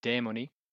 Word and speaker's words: Demoni: Uh, Demoni. It's very difficult to pronounce. It's got that long e sound.